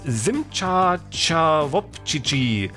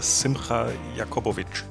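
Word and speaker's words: Simcha Jacobovici: Simcha Chawopchichi. Klingt ja scharf gewürzt. Habe ich das richtig ausgesprochen? – Ja, man spricht, glaube ich, so ungefähr Simcha Jakobovic.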